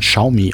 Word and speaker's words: Xiaomi: Äh, du hast mir irgendwie so ein China äh, Xiaomi, ich, ich habe jetzt gelernt, das spricht man Xiaomi ah, aus. Xiaomi, ja, okay.